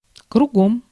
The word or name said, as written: кругом